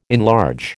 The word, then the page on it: enlarge: American English